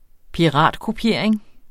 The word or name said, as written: piratkopiering